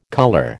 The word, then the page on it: colour: American English